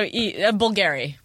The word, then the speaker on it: Bulgari: So Bulgari. – Bulgari. Okay. – Spelled B-O-L.